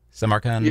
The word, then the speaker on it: Samarkand: Speaking of certain sounds and such, just short of 3 minutes in Shanghai to Samarkand, if I'm pronouncing that correctly, Samarkand? – Yeah.